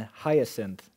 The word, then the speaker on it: hyacinth: The riders had breastplates, the color of fire and hyacinth. That's how you pronounce it.